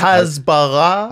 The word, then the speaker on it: hasbara: Thank you so much for coming on Bad as Barra. We really appreciate it. – I'm sorry. I'm sorry. Excuse me, but you're mispronouncing the word. – It's Hasbara.